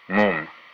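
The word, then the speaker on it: Múm: Entonces, esa u con acento se pronuncia u, mum, mum.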